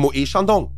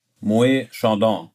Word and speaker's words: Moët: Wie spricht man Moët Chandon richtig aus? – Moët Chandon. – Falsch. – Okay, Moët Chandon. – Moët. Ah, okay, tatsächlich, ja? – Tatsächlich.